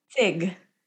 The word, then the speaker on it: zig: Zig. Z-I-G.